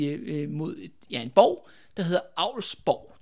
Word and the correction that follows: Alvsborg: tror jeg nok, det udtales. Eller, øh, skal de se, hvordan det er. Øh, A-L-V-S-borg. Alvsborg. Ja, Alvsborg. Ja, det lyder meget rigtigt.